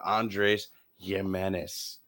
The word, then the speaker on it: Andrés Giménez: Let's talk about a shortstop here that's doing great, and that's Mr. Andrés Giménez.